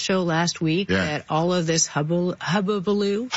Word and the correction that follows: hullabaloo: Hullabaloo. – Hullabaloo.